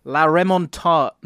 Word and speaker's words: La Remontada: First of all, La Remontada. Sorry, I nearly said it wrong there.